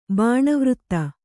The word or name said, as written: ಬಾಣವೃತ್ತ